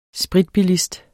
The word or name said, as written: spritbilist